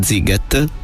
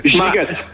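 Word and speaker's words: Sziget: Giget, dovrebbe essere Giget. – Dovrebbe essere Giget, come Gigan di Lupin. – Esatto, però un po' però con una, esatto, ma con la T alla fine. Quindi Giget.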